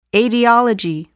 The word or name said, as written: aetiology